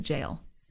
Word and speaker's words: gaol: So that word G-A-O-L is actually like it's a Middle English word. You pronounce it jail.